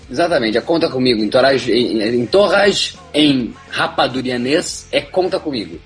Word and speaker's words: Entourage: Entourage.